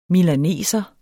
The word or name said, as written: milaneser